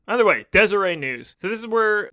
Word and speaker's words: Deseret: So in the, I believe it's the Deseret News. It's spelled D-E-S-E-R-E-T. Sounds like Deseret to me.